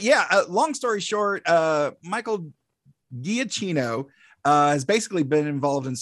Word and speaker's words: Giacchino: Michael, I always mispronounce it. Giacchino. Giacchino, thank you.